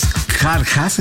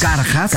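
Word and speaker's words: Kjarkas: Esta fue grabada por la banda boliviana Los Jarjas, espero estar pronunciando bien porque me cuesta mucho trabajo, porque es K-J-A-R-K-S. – Los Jarjas, Carjas. – Carjas.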